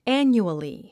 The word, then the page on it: annually: ǽnjuəli; アニュアリィ